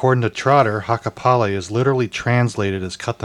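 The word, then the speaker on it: hakkaa päälle: It rhymes with acapella. – Acapella. – Yeah, so Hakapale.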